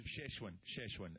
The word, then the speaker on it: Szechuan: Is it Shoshquan? I can never pronounce it properly. – It's something like that. I don't know. – It's sort of a spicy... – Szechuan.